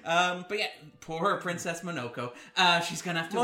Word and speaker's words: Princess Mononoke: I can make Graham quiver when I say Princess Mononoke. – That was correct. – That was very correct.